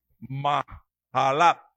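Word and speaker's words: Maalat (מחלת): Para engavetarla, no, para cantar, y dice, para cantar sobre Amahalat, o Maalat, como usted lo quiera leer, porque es que esa J en el, esa H en el hebreo se pronuncia como J, entonces es Maalat.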